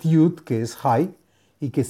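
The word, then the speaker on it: Hai (חי): Está recomendada por la página de Facebook Kabbalah y Torah en expansión y trae un nombre sagrado para meditar que son las letras Het Yud y se pronuncia Hai.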